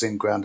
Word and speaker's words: NGRND: But I think in order to understand it, you've got to look at what Ingrand are trying to do. And of course, Ingrand is spelled NGRND.